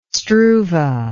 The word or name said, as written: struve